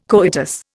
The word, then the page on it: coitus: Indian